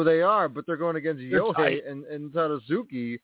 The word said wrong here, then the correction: Tadasuke: Tadasuke, yeah.